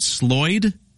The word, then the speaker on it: Sloyd: Now, first, my very first question, am I pronouncing this right? Sloyd? – That's correct. Sloyd Experience.